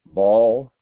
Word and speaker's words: Ball: Ball, B-A-L-L.